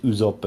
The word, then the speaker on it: Usopp: Invece, stiamo parlando già di Usopp, che finalmente hanno pronunciato bene per tutta la serie, perché effettivamente Usopp è giusto.